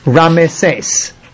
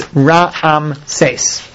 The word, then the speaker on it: Rameses: It's a moving shva because it's starting the next syllable, mm-hmm. and it gets pronounced rameses. Whereas on 294, it's ra'amses.